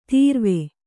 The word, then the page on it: ತೊರವಿ: Kannada